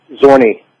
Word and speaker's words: Zhorne: Zhorne.